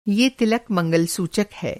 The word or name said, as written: मंगल-सूचक